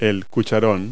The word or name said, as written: bucket